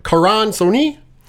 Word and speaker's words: Karan Soni: His real name, Karan Soni.